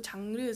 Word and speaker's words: 장르: So 장르 is just like genre in Korean pronunciation.